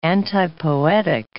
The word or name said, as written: antipoetic